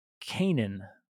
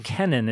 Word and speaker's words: Kenan: So Seth has Enosh, and then Enosh has Canaan or Kenan. It's spelled K-E-N-A-N or C-A-I-N-A-N.